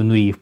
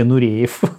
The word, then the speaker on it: Нуриев: То есть в, России, в Италии каждый знает, кто такой Нуриев. вы почему-то говорите Нуреев. – Нуреев. Нуреев, Нуреев. Нуриев. – Нуриев. А, Нуриев. Вот.